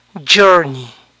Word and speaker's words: journey: Viaje, recorrido, journey, journey. Este final suena ni. Journey, honey, money, Disney.